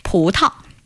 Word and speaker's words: 葡萄: 葡萄. – 葡萄.